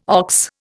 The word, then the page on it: ox: Indian